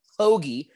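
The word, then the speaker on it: Hoge: I can confirm that first round leader, Tom Hoagie, is how you pronounce the name, like a sandwich.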